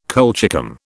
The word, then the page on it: colchicum: British English